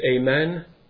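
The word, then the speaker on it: amen (ἀμήν): It's the word in the Greek language that's pronounced amen.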